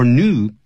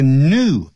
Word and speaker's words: new: In addition, by finding whether the shoes are old or new, one can see the owner's fashion styles. 这个刚才念这个 new, N-E-W, 变成 new, 念成 new in American English.